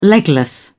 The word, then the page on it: legless: British English